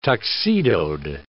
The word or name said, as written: tuxedoed